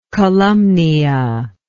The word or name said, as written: columnea